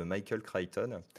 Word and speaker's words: Michael Crichton: Je vous présente euh, Jean-Michel Crichton, également connu sous le nom euh, anglais correctement prononcé en anglais de Michael Crichton.